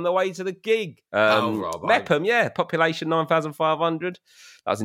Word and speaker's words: Meopham: M-E-O-P-H-A-M. – Oh, Meppam. – Meppam. Meppam. – Fucking Meppam, mate. Meerfam, you fucking stiff-necked dick. – I'm sorry, I don't know the exact pronunciation of every place in Kent.